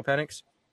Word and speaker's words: Penix: Michael Penix.